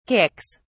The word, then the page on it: kicks: British English